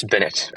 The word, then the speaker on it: Binit: Yes, Binit. As in bin it, toss your trash into the bin.